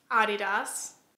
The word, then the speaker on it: Adidas: In my last video, I pronounced Adidas. Adidas, which apparently is the correct pronunciation because the founder is German, but in America we pronounce it Adidas, and in a lot of other places people call it Adidas, so people were very bothered by me pronouncing it that way, but like technically it's right.